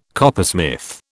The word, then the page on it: coppersmith: British English